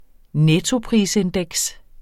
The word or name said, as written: nettoprisindeks